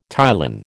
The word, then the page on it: ptyalin: American English